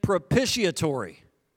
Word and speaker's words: propitiatory: He offers both thanksgiving and propitiatory. It's a cool word. Am I saying it right? Yeah.